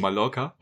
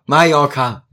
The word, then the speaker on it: Mallorca: Wie wird das ausgesprochen? Ich hau hier gleich so dermaßen ins Gesicht. Mallorca? – Mallorca! – Mallorca. Mallorca. Wieder Mai. – Ja. – Mit Orca hinten dran.